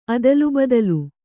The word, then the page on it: ಅದಲಾಬದಲು: adalābadalu